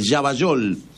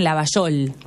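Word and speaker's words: Llavallol: Yavayol. Yavayol, pues se escribe Yavayol, no, Yavayol, ¿cómo decís llave? ¿Llave o llave? – Llave, pero muchos le dicen Lavayol. – Bueno, pero es con doble L.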